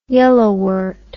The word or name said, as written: yellowwort